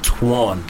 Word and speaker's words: Twan: It's no, Twan. – Yes, Twan the Dawn. – It's like the way English people say twat versus the way Americans say twat. – But at the same time, it's the same way we say swan. Twan.